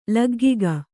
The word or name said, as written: ಲಗ್ಗಿಕ